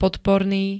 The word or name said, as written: podporný